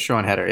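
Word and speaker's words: Siân Heder: So is it Sean Hedder? Is that how you pronounce for Coda? – I think it's Sean Hedder, yeah. – Oh, I got it right.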